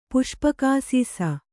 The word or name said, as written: ಪುಷ್ಪಕಾಸೀಸ